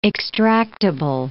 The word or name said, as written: extractable